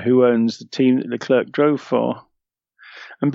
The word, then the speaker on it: Leclerc: His name is Leclerc. The C is silent, as in... No, I won't go there. – The C's are silent, so Leclerc.